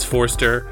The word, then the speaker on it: Forster: It's Forster. A lot of people spell the last name wrong. It's very important. Forster. Exactly how it sounds. Don't throw an extra E in there. Don't mess it up.